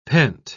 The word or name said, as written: pent